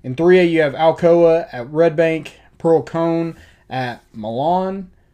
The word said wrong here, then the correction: Milan: I think I'm saying that right. M-I-L-A-N. – It's Milan. – Milan? Okay. Mm-hmm.